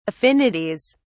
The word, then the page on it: affinities: British English